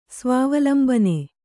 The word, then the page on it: ಸ್ವಾವಲಂಬನೆ: svāvalambane